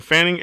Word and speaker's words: Fanning: S-H-E-D-A-R-R-E-N. Last name Fanning, F-A-N-N-I-N-G.